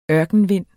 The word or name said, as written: ørkenvind